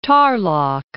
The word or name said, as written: Tarlac